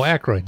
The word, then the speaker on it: Aykroyd: I'm going to butcher this. Aykroyd. I think it's Aykroyd. Aykroyd. Whatever. 72. Right. Canadian born Saturday Night Live. – Oh, Aykroyd. Dan Aykroyd.